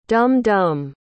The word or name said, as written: dum-dum